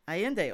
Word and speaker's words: Allende: I know I'm not going to say her name right. Isabel Allende. Allende. Okay.